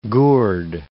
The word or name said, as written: gourde